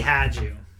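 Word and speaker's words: Hajdu: Her last name had some like a silent J or something. Or a silent something. – It is Pat. – I know it's Pat. – Damn it. It's Patty Haju.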